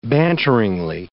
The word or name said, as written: banteringly